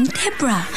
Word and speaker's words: Debra: There is no O in Deborah. Now that you've got the name roughly right, it's Deborah, D-E-B-R-A.